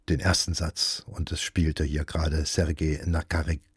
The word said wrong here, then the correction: Sergei Nakaryakov: Na, jetzt wollen die Silben nicht so locker fließen. Also, Sergei Nakaryakov spielte die Trompete, das St. Paul Chamber Orchestra begleitete ihn dabei.